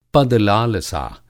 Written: पद-लालसा

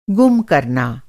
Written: गुम

